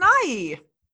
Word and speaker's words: Nighy: It's Nighy, yeah. – What? Hold on. – Is that going to be this episode's title? We're going to go from There's a Muppet Named Gonzo to It's Pronounced Nighy.